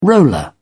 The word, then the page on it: roller: British English